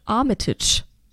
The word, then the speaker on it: Armitage: sondern auch in dieser Serie spielt Richard Armitage. Armitage, ich weiß es nicht. – Ricciardi Armitage. Ich glaube, du hast beim erstmal richtig ausgesprochen.